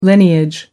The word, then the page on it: lineage: American English